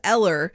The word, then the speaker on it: Eller: Her real name, Ella McNamara, calling herself Eller, because that's the way they pronounce it in her hometown of Halifax in England.